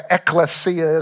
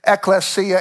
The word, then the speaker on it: ekklesia: The original word, the Greek word for church, is the English transliteration is ekklesia, is how that would be pronounced, E-K-K-L-E-S-I-A. Ecclesia, and it means literally an assembly, gathering, or congregation.